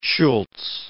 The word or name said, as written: Schulz